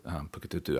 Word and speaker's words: Puketutu: and also for Puketutu Island, which, by the way, is how that's pronounced. Puke, P-U-K-E, is Māori for hill.